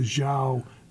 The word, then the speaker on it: Zhou: Talking about, I think it was called, pronounced the Zhou, Z-H-O-U dynasty.